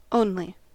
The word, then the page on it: only: American English